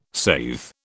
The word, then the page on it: saithe: British English